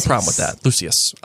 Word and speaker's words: Lucius: That's how they say it in the film. I don't know. Lucius. – Okay. – Well, that's how Voldemort says it, so I'm going to go with that. – Because he has a snake lisp. – Hey, don't lisp shame. – I'm not. I'm saying Voldemort says his name wrong.